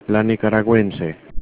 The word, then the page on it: nicaraguense: Spanish